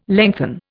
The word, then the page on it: lengthen: Indian